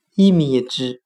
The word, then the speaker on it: image: 今天我们来学习单词 image，i m a g e，表示影像的含义。我们可以用谐音法来记忆这个单词 image，它的发音很像汉语的“ 一迷之”，意思的“ 意”，迷糊的“ 迷”，之前的“ 之”。